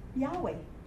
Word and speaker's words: Yahweh: Remember, the vav can be a wa. – Right. – Okay? That's why there is a Yahweh. Yahweh. Okay? Because the vav can be pronounced as wa or va.